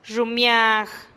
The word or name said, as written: xummiag